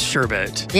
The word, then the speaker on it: sherbet: It is sherbet. – Yeah. – That's how you pronounce it. But you've been spelling it with an R. – Yeah. – Okay. Yeah. No, it's sherbet. – Yeah. I, it's, it's, it's yeah. not pronounced with a second R at the end of the word.